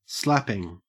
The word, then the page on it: slapping: Australian English